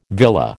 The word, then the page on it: villa: American English